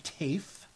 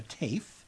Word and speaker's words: Taaffe: Then Sonia Taffe, it is pronounced T-A-A-F-F-E, but I have made sure because this is the radio. So Sonia Taffe. Shveta Thakrar, who describes herself, I just loved her, is Shveta Thakrar, that's S-H-V-E-T-A, T-H-A-K-R-A-R, is a South Asian-flavored fantasy writer, social justice activist, and part-time Nagini.